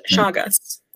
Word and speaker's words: Chagas: Chagas.